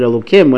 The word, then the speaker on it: Elohim: We pronounce it Elohim when it refers to God.